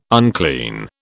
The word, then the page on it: unclean: British English